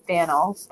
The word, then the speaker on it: Faneuil Hall: There, just across the way a bit is a place called Faneuil Hall. Actually, some of the locals, I think, pronounce it Faneuil, but Faneuil Hall is a great place.